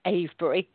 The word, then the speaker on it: Avebury: And that's A-V-E-B-U-R-Y, Avebury. But I love the way Americans say it, Avebury.